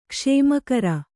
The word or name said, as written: ಕುಸುಮಾಕರ